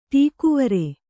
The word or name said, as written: ಟೀಕುವರೆ